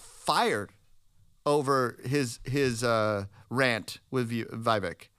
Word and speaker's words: Vivek: Have you watched any of these interviews that Vivek's been doing with all these different. Or how do you say his name? He's Vivek? – Vivek.